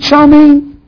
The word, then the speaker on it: Charlemagne: I was reading about that medieval king, and I can't get his name right. Charlemagne? Yeah? Great, powerful. Is it Charlemagne? Yeah? I got a nod, thank you.